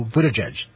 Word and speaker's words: Buttigieg: So Buttigieg, right? – Yeah, no, I that's right.